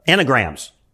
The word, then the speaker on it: Enneagram: The enneagrams. Enneagrams. How was that? Enneagram. That's how you pronounce.